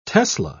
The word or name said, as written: tesla